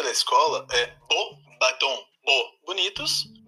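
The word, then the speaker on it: Beauxbatons: Então, como vocês acabaram de ouvir que me mandou esse áudio, é bobatom e não bobatô. Porque senão você estaria falando belos barcos.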